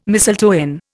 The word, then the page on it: mistletoe: Indian